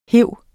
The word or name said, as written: hive